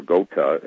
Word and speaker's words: Gotha: And it takes you back to Saxe-Coburg Gotha, or Gotha, as it's pronounced in German, which is the true seat of the British crown before they moved from Germany into England.